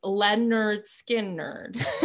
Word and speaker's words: Lynyrd Skynyrd: The album is helpfully entitled, pronounced Lynyrd Skynyrd. So that people would know how to pronounce it.